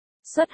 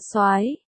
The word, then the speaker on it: soái: xuất hay còn được đọc là soái.